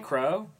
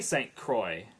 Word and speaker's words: St. Croix: Explain Carter St. Crow? – Is that how you pronounce that? – I don't know. – Okay. – Croix. – Yeah. – St. Croix, the island, is pronounced St. Croix.